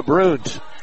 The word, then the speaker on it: Bruns: Handled there by Claire Bruns, B-R-U-N-S. This particular family pronounces it Bruns, and she drove to the basket, missed the shot, followed up and scored. Nice play by Bruns.